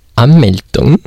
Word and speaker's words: Anmeldung: Like, the biggest thing you need to do is Anmeldung. Is my pronunciation correct? – That's very good, oh, yeah.